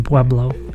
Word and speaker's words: Pueblo: In Pueblo, yes. – Okay. Oh, you say it like every other human says it.